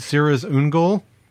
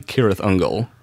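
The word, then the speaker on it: Cirith Ungol: You're pronouncing that incorrectly. – I'm sorry. – Allow me to flex my Tolkien. – Please. Tolkien. He's pulling it out right now. – Correct pronunciation would be Cirrus Ungol.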